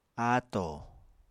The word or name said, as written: ato